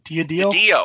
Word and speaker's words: DiDio: Didio.